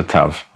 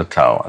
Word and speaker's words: tau: And the last letter of the alphabet is the tau, sometimes pronounced incorrectly as a tav or a taf, but correctly pronounced as a tau.